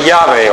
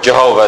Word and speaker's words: Yahweh: in the Hebrew it's the word Yahweh, or Jehovah as we like to butcher it.